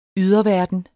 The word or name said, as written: yderverden